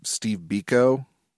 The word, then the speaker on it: Steve Biko: Steve Biko.